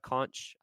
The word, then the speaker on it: conch: Maybe some, what is it, fried conch? I don't know how you pronounce it correctly. But That's right. That's exactly.